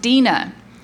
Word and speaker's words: Deana: For the record, my name is spelled D-E-A-N-A, and it is pronounced Dina. It's Dean with an And my last name is Nall, like Mall with an N.